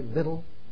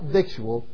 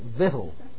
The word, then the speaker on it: victual: Victual? Not victual, victual. That is the proper pronunciation, not just the East Texas pronunciation. Sort of like schism and schism. Vittle.